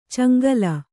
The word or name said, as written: ಚೆಂಗೂಲಿ